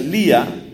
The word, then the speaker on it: Leah: Hay una mujer de nombre Lea, creo que se pronuncia Lía, L-E-A-H.